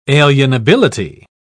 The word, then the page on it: alienability: British English